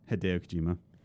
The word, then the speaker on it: Hideo Kojima: Hideo Kojima.